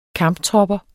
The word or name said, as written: kamptropper